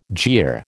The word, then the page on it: jeer: American English